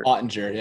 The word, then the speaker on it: Oettinger: like the Tendy Audiger. Is that how you say his name? Jake Audiger. He plays for Dallas, whatever. – Oettinger, yeah. Um, yeah every time he drink water, like it'd spill on it.